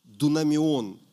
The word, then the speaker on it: дунамион: Я вам говорю, как есть сейчас – Сил – это дунамион слово. Дунамион, название, точнее ударение на А. Дунамион, запомните.